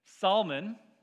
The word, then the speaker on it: Salmon: And Rahab begat Boaz with Salmon, and it's spelled salmon, but he's not a fish, so I'm including the L in the pronunciation there. Salmon.